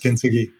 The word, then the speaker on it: kintsugi: And correct me if I pronounce it incorrectly, but the ideology around kintsugi. – Yes. Yes, kintsugi. – And oh, perfect. I'm glad I got that right.